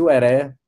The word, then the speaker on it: Wèrè: Queria deixar esse convite para você que está ouvindo, entrar na sua plataforma preferida e procura lá Saci Ueré, o Heré se escreve com W, W-E-R-E, e escuta o nosso álbum Cabeça Sem Tampa,